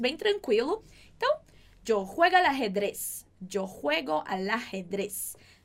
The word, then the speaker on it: al ajedrez: Só um cuidado com essa pronúncia. Al ajedrez. Ok? Porque a próxima palavrinha começa com uma vogal. Então, você junta o L com o A.